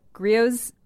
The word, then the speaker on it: Griot's: The Griot? – Griot? Will you spell it? – G-R-I-O-T-S.